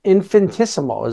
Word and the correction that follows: infinitesimal: isn't it? – You're so close. Infinitesimal. – I'm missing a syllable.